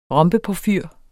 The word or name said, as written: ROMBEPORFYR